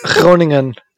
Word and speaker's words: Groningen: Groningen. It's like the Groningen. It's probably 40 miles from Groningen. I don't even know if I'm saying that right. – Groningen is the word you're looking for.